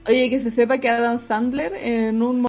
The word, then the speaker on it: Adam Sandler: Y en ese momento tú tenés que aprovechar así como para venderle la eso lo aprendí con una película de Adam Sandler. – ¿De Adam quién? Adam Sandler. Pronuncia bien la weá.